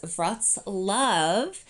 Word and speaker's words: Wrocław: Wrocław, we pronounce it as Wrocław, is located in southwestern Poland and is one of the largest cities in the historical region of Silesia.